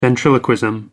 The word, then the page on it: ventriloquism: American English